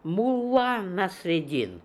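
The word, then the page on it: Nassr Eddin Mullah: Russian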